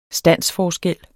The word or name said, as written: standsforskel